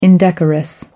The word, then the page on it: indecorous: American English